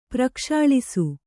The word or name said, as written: ಪ್ರಕ್ಷಾಳಿಸು